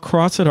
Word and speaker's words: Crossett: Tell me about being a little boy growing up in Crossett. Am I saying that right? Crossett, Arkansas. – It's called Crossett, Arkansas.